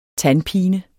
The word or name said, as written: TANDPINEN